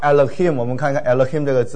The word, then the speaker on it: Elohim: 所以第一个字读得很快，Elohim 啊，第一个音很短，Elohim。